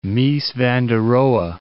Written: Mies van der Rohe

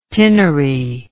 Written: tinnery

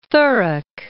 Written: Thurrock

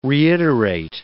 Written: reiterate

